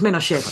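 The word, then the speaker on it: Ménochet: Eh, ya me disculpo de antemano por si pronuncio mal los nombres de los franceses, Denis Michonet, que interpreta a Antoine, el francés. ¿Por, porque has dicho Michonet? Michonet, Menochet, eh, es Menochet, perdón.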